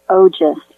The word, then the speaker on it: Ojas: So I'll tell you what that is first, and it's called Ojas, O-J-A-S. And Ojas, there's really no translation in English. So just take that at its face value. Ojas is something that gets produced,